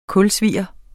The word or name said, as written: kulsvier